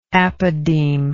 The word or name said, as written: apodematal